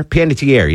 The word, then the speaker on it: Panettiere: Panettiere, yeah.